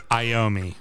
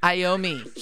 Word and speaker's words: Iommi: Tozy Imey? – Iommi. – Iommi. – He's rock and roll royalty, for fuck's sake. You should know how to pronounce the lead guitarist of fucking Black Sabbath's fucking name.